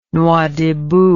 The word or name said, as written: Nouadhibou